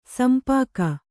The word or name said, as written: ಶಂಪಾಕ